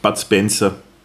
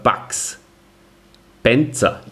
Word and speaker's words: Bud Spencer: Und zwar, hast du Bud Spencer, wie hast du den immer schon so ausgesprochen oder wie hast du den früher ausgesprochen als Kind? – Bud Spencer. – Wirklich? – Ja. – Okay. Ich zum Beispiel habe mir immer gedacht, der heißt Bugs Spencer.